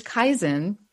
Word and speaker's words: Kaizen: Yeah, so it's Kaizen. Which was actually, I'll tell you a very brief history of what Kaizen is.